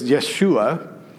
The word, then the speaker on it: Yeshua: Joshua, so much so that his name pronounced in Hebrew is Yeshua, which, by the way, is the same name of Jesus, because Jesus is the Greek pronunciation of the Hebrew Yeshua.